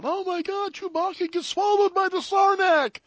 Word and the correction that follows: Sarlacc: Yeah, Sarlacc.